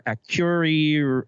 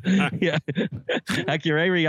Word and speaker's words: Akureyri: Akure. You know what? I had this pronounced properly earlier. Akureyri, Iceland. Akureyri, Iceland.